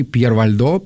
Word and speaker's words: Пьер Вальдо: Был такой Петр Вальдо, или, если правильно говорить по-французски, Пьер Вальдо, потому что он был французом, он был из города Лиона, был купцом.